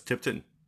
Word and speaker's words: Tipton: It really seemed like Scott and David Tipton? Yes, Tipton.